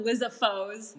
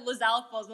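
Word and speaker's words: Lizalfos: When I would, you know how I would pronounce Lizalfo's names? I would pronounce them Lizafo's. – Lizafo's. – Like, that's when I read them, but then my friend said Lizalfo's. I'm like, that's how they're pronounced? – Yeah.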